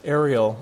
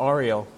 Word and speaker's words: Ariel: My name is Ariel, or Ariel. You can say it like the crab says it, if you want.